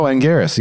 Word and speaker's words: Anguirus: It says, I bet Anguirus, is that how you pronounce it? – Oh, Anguirus, yes.